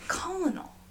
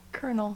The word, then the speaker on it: Colonel: That word is spelled so weird. – It's Colonel. – Colonel. – Why did I not connect the dots to Colonel?